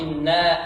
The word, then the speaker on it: إنا: لا، عندك الهمزة هذه لم تأتي بها محققة. إنا قلت إنا أنزلناه، يعني أتيت بها فيها تسهيل يعني، لكن نطقها السليم هي محققة، إنا أنزلناه.